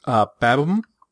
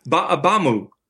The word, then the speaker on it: Ba'abamu: The last one you had on your list is Babam. – Except it's pronounced Ba'abamu.